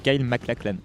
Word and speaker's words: MacLachlan: Comment tu prononces le nom de l'acteur? – Moi, je dis Kyle McAllen. McLand, je ne sais pas si j'ai la prononciation parfaite, nom, c'est Kyle MacLachlan. Moi, je prononce ça comme ça.